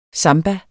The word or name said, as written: samba